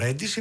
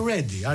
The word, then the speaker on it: Ready: Το Ready δεν είναι ο Ready, είναι Ready. Are you ready? – Πολύ ωραία το είπες, εγώ σε θαύμασα.